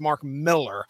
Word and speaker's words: Mark Millar: Yeah, from what I understand, his name is pronounced Mark Millar.